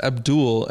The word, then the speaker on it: Abdel: His name is actually, it's spelled Abdel, but it's Abdul, and they call him Dooley.